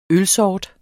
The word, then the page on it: ølsort: [ -ˌsɒːd ]; [ -ˌsɒˀd ]